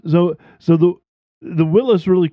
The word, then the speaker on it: Willys: So the S, it's Willys S and Willys. – Yeah. – Willys. Willys and, would be the right way to say it.